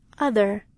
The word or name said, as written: other